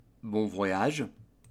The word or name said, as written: bon voyage